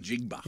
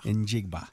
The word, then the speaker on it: Njigba: Njigba. – Njigba.